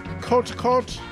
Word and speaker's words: Kot Kot: Prior to that, it was Cote Cote, spelled K-O-T, K-O-T, two words.